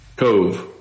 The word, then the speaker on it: Cove: Now, is it pronounced Cove or Cole? Because I've heard it both ways. – Cove. V-E. Cove. – Oh, yeah. It is the V-E is pronounced.